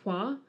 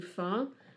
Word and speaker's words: Hua: So you will have noticed in the remake, they pronounce her family name as Hua, as opposed to Fa. That is the difference between the Cantonese and Mandarin pronunciations of her name. – Okay. – So it should be pronounced Hua, I think, but in Cantonese it's pronounced Fa, so she'd be Fa Mulan.